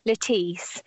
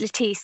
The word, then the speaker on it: Lettice: Lettice. It's Lettice. So it's a shortened form of Letitia, which was the Latin word for happiness.